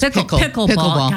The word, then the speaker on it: pickleball: Because I've never really heard of pickerball. – It's pickleball.